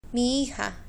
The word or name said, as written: mi hija